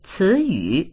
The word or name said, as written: ci2yu3